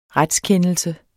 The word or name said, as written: RETSKENDELSER